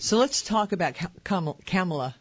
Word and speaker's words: Kamala: Well, I called her that, too, until I heard her say her name. Kamala.